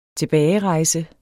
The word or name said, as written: tilbagerejse